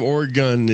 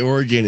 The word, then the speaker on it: Oregon: I'd say Oregon, and he'd correct me angrily and say it's Oregon, like the gun I'm going to shoot you with if you say Oregon again.